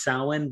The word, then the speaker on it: Samhain: It's spelled like it's Samhain, but it's pronounced Samhain because Irish.